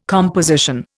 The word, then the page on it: composition: Indian